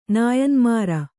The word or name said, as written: ನಾಯನ್ಮಾರ